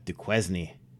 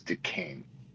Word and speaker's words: Duquesne: Yeah, Duquesne. – Duquesne. – Yeah, Duquesne, yes, of course. But yeah, it looks like a weak name, but it's Duquesne.